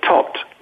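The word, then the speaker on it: Tóth: And the same with Daniel Tott. I'm reliably informed by a Czech Republic, Hungarian compatriot, that that's how you pronounce it. The H is silent.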